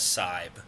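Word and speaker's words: Psybe: I'm known in these parts as Sybe, which is a P-S-Y-B-E. The E is silent.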